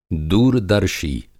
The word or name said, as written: दूरदर्शी